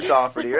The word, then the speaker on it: Daugherty: Brant, B-R-A-N-T, D-A-U-G-H-E-R-T-Y. It's pronounced Daugherty, not Daughtry or Daugherty or Daugherty or any of the other things. It's Daugherty.